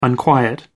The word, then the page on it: unquiet: American English